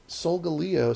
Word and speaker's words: Solgaleo: Yeah, and as the guy at the in the Invitational called it, Sogaleo. – Sogaleo? – Yeah, Sogaleo. Solgaleo is how I always pronounce it.